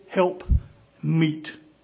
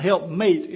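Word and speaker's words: helpmeet: Not help mate, M-A-T, but help meet. Okay? She was meat for him. And not meat like meat to eat, but meat. Amen? My pronunciation sometimes makes it think like I'm talking about pork chops or something. But a help meet for him.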